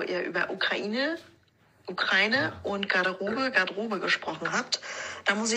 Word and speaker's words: Garderobe: Ähm, und es wird auch im Franzose französischen Garderobe ausgesprochen. Wenn, dann würde das E am Ende halt hinten wegfallen.